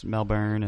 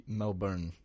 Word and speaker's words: Melbourne: So you just kind of tooled around Melbourne and am I saying that right? Melbourne. – Melbourne. – Melbourne. – You can't have that E. They'll spot you.